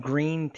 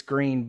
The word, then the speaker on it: Gruene: in Gruene, Texas. It it it obviously sounds like it's green, but it it looks like it's pronounced Gruen but uh, which is like a cool thing.